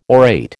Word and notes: orate: American English

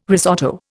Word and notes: risotto: Indian

